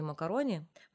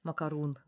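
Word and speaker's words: macaron: И ей София Коппола, режиссер этой картины, прислала коробку печенья макарони, макарун, да, по-разному произносят.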